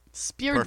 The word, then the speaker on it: Spiridonov: So this guy is, his name is Valerie Spiridonov. – Spiridonov. – Perfect pronunciation. – Thank you so much. All the Russians are like, it's the Spiridonov.